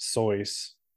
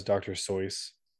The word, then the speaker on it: Seuss: So basically, I do know Dr. Seuss's name is supposed to be pronounced Sois, but no one knows him as Dr. Seuss, so no one's gonna change it.